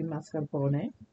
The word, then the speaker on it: mascarpone: Really, in Italian, you pronounce the E at the end. It would be mascarpone, but it is mascarpone at an American setting.